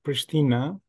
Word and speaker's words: Pristina: The capital of Kosovo is pronounced Pristina, and Kosovo is a new country which declared the independence from Serbia in 2008.